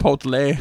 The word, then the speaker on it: Chipotle: No, you got to think of like a Spanish twist on that. – Chipotle. – Yeah, that's actually how you say it.